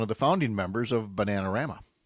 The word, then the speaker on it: Bananarama: Bananarama. – Bananarama.